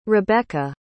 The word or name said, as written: rebecca